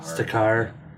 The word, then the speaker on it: Stakar: Stakar.